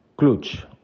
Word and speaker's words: Clutch: La revista que hoy os quiero recomendar se llama Clutch o Clutch, si la pronunciamos en, inglés, em, y está dedicada al estilo de vida masculino.